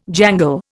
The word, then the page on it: jangle: Indian